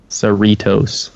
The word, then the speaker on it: Cerritos: Cerritos. – Like Cheetos? – Close. That might be the Indiana version, but yeah.